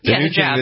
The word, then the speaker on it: hijab: All right, so let me just go back to this hijab. Is that how you pronounce it? – Hijab. – That Nike is a hijab, is that what it's called? – Hijab. – Hijab. – Yep.